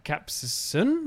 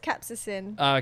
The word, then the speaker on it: capsaicin: Oh, and it's pronounced capsaicin. – What was I calling them then? – Capsaicin, maybe? – Capsaicin. No, because I knew it's capsaicin.